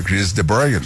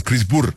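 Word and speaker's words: de Burgh: Y entonces le preguntamos eso, ¿cómo se pronunciaba su apellido? Entonces él dijo, bueno, se pronuncia Chris de Bourne, lo pronunció de una forma. Dijo, pero es que nadie lo puede pronunciar bien. – Ajá. – Nos contó que en Rusia le decían Chris Burr. Chris Burr.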